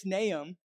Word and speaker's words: Nahum: It's Nahum, but it's just naturally you want to pronounce the H so strongly and the U and all that stuff.